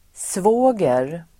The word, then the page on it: svåger: [sv'å:ger]